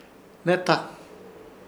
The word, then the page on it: Hnehta: Ishir; Chamacoco